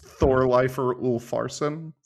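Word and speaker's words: Þorleifur Úlfarsson: That's the letter Thorn. Thorn. It's pronounced like a th. It's Thorleifur Ulfarsson. – See, that's what I'm talking about.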